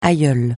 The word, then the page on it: aïeule: francais